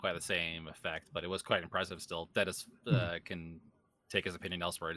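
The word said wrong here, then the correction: Denis: It's Denise, right? – Yeah.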